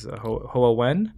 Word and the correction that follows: Hua Wen: Oh, Hua Wen, yeah. – Hua Wen, okay. – Only because I've heard her pronounce it, or her pronounced, yeah.